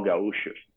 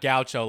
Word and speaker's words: Gaúcho: So the Gaúchos are the ones who does the real barbecue, and the the region I was born is all Gaúchos, you know. So that's why they call Gaúchos. Yeah. – Okay, so it's not Gaucho like my American stupid pronounced. – He has no Gaúcho. – Gaúcho. Got it.